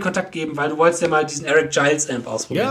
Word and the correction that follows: Eric Gales: Ja, von dir. Gales. – Eric Gales wird's ausgesprochen. – Er wird tatsächlich Gales ausgesprochen, ja.